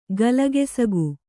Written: ಗಲಗೆಸಗು

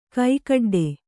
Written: ಕೈಕಡ್ಡೆ

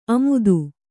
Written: ಆಮೋದ

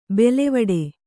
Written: ಬೇಲಿವೀಡು

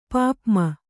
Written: ಪಾಪ್ಮ

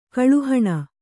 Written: ಕಲಾಹೀನೆ

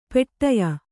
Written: ಪಟ್ಟೆಯ

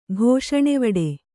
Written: ಘೋಷಣೆವಡೆ

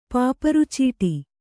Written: ಪಾಪರ್‍ಚೀಟಿ

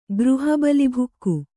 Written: ಗೃಹಬಲಿಭುಕ್ಕು

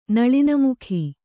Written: ನಳಿನಮುಖಿ

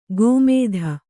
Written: ಗೋಮೇಧ

